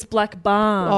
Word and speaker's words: Blackburn: Am I saying that right? – I think it's Blackburn.